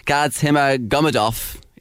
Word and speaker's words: Gadzhimagomedov: Muslim Gadzhimagomedov.